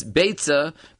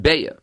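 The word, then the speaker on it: Beitza (ביצה): We can bring a raya from the Rabbeinu Hanano right over here in the beginning of the Masechta that he definitely pronounced it with the tzaddik, because the Lashon of the Rabbeinu Hanano, he has a poem in the beginning of the Masechta, and he says as follows, Aschil Maseches Beitza Be'ezras Gdoil Ha'etza. It wouldn't be a rhyme if he would have pronounced it Beya.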